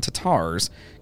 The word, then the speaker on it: Tatars: I don't know if you saw that, but it's pronounced taters. – It's not taters. That one, I know that one is not taters.